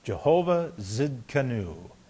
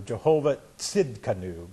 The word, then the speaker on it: Jehovah Tzidkenu: Or to pronounce it a little more closely to the Hebrew, Jehovah Tzidkenu, because it's tzedik is the root from which it comes from.